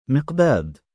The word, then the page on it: miqdaad: male